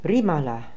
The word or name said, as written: Rimala